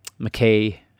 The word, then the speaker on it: Mackay: And so here we tend to pronounce it Mackay, but the original pronunciation was Mackay.